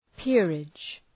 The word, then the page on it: peerage: ‘pırıdʒ